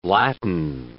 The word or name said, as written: latten